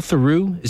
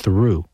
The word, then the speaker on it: Theroux: I've got that and I've got Paul Theroux, is that how his name is pronounced? I don't think it's pronounced Theroux. I think it's pronounced Theroux.